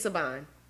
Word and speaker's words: Siobhan: Saban. It's Saban. – I think that makes sense. I'm like, first of all, I listened to the book. Well, I should know everybody's name. It's Saban.